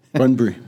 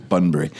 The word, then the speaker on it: Bunbury: Bunbury. – Bunbury.